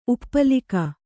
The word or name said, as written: ಉಪ್ಪಲಿಕ